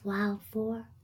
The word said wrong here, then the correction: wildflower: Not wild for. It's wildflower, bee. Wildflower.